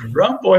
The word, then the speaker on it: Rimbaud: Rambo, é como se fala Rimbaud lá em inglês, né?